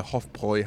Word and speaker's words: Hofbräu: and in the very last version of proofs, I saw one mistake, and it was Hofbräu. And it was a Hofbräu house in Berlin it was referring to and it was spelled wrong, and I corrected.